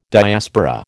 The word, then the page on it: Diaspora: American English